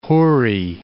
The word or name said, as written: houri